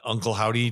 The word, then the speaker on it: Uncle Howdy: The Bobby Lashley and Uncle Howdy. Did I say it right? – Oh my God. – Did I say it right? – Yeah. Way to go.